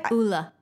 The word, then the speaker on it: Ole: So it's the Uli Henriksen, or how, how do you say it? Ula. Ula. Yep.